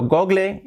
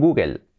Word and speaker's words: Google: La L al final casi no se pronuncia, está muy ligería ahí. Google. Pero hay que pronunciarla un poquito porque nos cambia la vocal. Google. Como Apple.